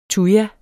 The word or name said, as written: tuja